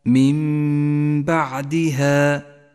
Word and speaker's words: من بعدها: النون الساكنة وبعدها حرف الباء تقلب النون إلى ميم وتسقط من اللفظ تماما ولذلك لونت النون باللون الرمادي إشارة إلى عدم نطقها ولونت الميم باللون الأخضر لأن حكم الإقلاب يحتاج إلى غنة زمنها حركتان أي هكذا